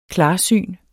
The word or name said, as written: klarsyn